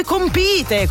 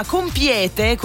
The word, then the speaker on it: compite: Poi nel frattempo mi arriva un altro messaggio in cui mi si dice, screenshot della schermata, si dice voi compite con l'accento sulla I. La forma compiete con l'accento sulla E è errata,